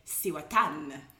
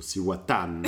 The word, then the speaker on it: Cihuatán: No, the accent's on the A at the end. So, I think for my Spanish lessons, it's like Cihuatán. – So, Cihuatán. I am pouring two glasses of Cihuatán, which is an edge rum that we purchased while we were traveling to Salvador.